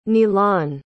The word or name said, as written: nilan